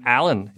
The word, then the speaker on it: Alain: I'm so French-Canadian, my name, Alan, is spelled A-L-A-I-N.